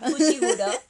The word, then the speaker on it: Fushiguro: Fushiguro, Fushigami, como sea. – Fushiguro, así es, conoce a Fushiguro.